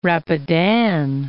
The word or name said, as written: Rapidan